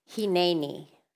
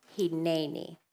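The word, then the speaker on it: hineni (הִנֵּנִי): In Hebrew, the word surrender means, let me get my pronunciation, hineni, hineni.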